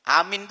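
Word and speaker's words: amin (آمين): Afsahuha, yang paling fasih adalah amin bil maddi, hamzahnya dipanjangkan a wa takhfif min, mimnya tanpa bertasydid, amin.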